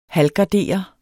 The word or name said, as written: halvgardere